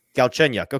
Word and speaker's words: Galchenyuk: Galchenyuk.